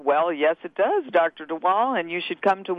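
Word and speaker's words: de Waal: That Dr. DeWall, am I pronouncing his name right? – DeWall. – Yeah, DeWall.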